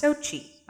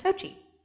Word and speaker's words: Xochitl: It's pronounced Xochitl, I think. It's spelled Xochitl, but it's pronounced Xochitl.